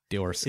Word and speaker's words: Dworkin: Dworkin. – It just rhymes with one of my favorite words.